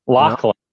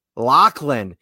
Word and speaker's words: Lachlan: I thought LaShawn, how do you pronounce his first name? – Lachlan. – Lachlan. He's from Australia. – So not a lock right now, it sounds not like.